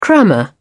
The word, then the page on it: crammer: British English